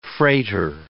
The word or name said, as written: freighter